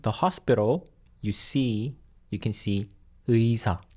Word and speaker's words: 의사: You just need to quickly pronounce 의 and 이 together like 의, 의, 의사.